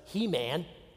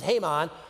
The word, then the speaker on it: Haman: You might have been tempted to pronounce it He-Man. And the masters of the, you know, it wasn't He-Man. It was Haman.